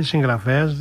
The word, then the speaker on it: συγγραφέας: Η Μπέτη μας λέει το εξής: με μεγάλο σεβασμό και αγάπη θέλω να σας επισημάνω πως τη λέει συγγραφέας δεν προφέρεται όπως γράφεται. Προφέρεται με διακριτό νι. Συγγραφέας.